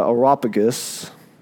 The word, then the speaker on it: Areopagus: So Paul standing in the midst of the Areopagus.